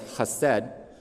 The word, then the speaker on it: chesed: In the Hebrew, it is pronounced as chesed, which can also be translated as His loving kindness.